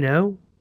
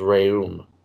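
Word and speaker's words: Rhéaume: Isn't it Raynaud? – No, I believe it's pronounced Raume.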